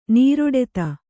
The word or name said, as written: ನೀರೊಡೆತ